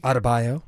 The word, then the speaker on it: Adebayo: Adebayo.